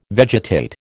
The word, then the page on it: vegetate: American English